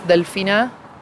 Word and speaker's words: Delfina: My name is Delfina Campos, which is spelled D-E-L-F-I-N-A. Campos is C-A-M-P-O-S.